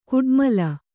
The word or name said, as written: ಕುಟ್ಮಲ